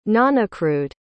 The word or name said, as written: nonaccrued